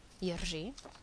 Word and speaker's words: Jiří: and this is when the an R occurs with the háček on it, and it's pronounced as an R and a Ž together, so it's Rž, Jiří, Varšava.